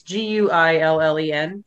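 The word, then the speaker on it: Guillén: Harvey Gulen, or I'm not sure how you pronounce his last name. It's Guillen.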